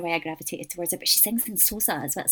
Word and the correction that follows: Xhosa: Sorry, I'm gonna say this properly. Xhosa. There's a click in there, she uses that language in her songs as well as English.